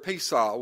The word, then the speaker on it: Pesah: The word for Passover is the word Pesah, which is spelled P-E-S-A-H. And it is from the word Pasah, P-A-S-A-H, which means to pass over, to leap over, to skip over, to spare.